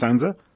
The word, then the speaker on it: SANSA: And I hope I got all that designation correct, Managing Director, and it is Sansa, pronounced Sansa. – That's correct. It's perfect.